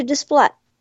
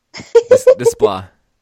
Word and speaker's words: Desplat: Alexander Desplat. – Yes. – So, Mark. – How do you say that name? – Desplat. – Desplat, okay. Just don't add the T, okay.